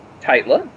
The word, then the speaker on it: Tytla: How do you pronounce that? Tytla. Tytla.